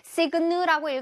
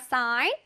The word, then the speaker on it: sign: 이것도 또 signal라고 읽으면 안 된다 그랬어요. sign이에요, sign. Okay? 그 소리가 안 나요? sign.